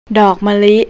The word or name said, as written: mali